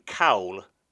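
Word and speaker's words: cawl: But Stu, interestingly, I found out this week that I've actually been pronouncing call incorrectly. It's apparently cowl.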